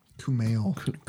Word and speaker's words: Kumail: But again, I mean, so here's the thing, like, like Big Sick is far more about, it's pronounced Kunal, right? – Kumail. – Kumail.